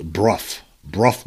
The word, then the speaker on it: Burgh: It's actually spelled B-U-R-G-H, but according to Mr. Conrad, it is pronounced Brough Marsh.